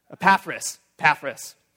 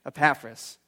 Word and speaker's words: Epaphras: Epaphras, thank you. Okay, Epaphras.